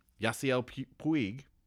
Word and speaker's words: Yasiel Puig: But if you took that money and signed Yasiel Puig – did I say that right? – Yeah.